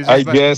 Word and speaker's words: Alguer: Ecco, vedi, Alger senza la R, si pronuncia senza.